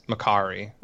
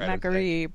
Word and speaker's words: McCree: Velen pronounces it in game as McCary, I think. – McCary. – So because he says it out loud during the. – I always call it McCree, but it's McCary.